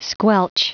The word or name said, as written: squelch